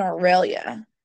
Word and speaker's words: Aurelia: My favorite couple was Jamie and Aurelia. – Oh, yes. Who is played by Colin Firth. – Colin Firth. – Which I love him. And you said her name correctly.